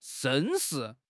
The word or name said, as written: 绅士